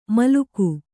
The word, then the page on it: ಮೇಲಕ್ಕ: Kannada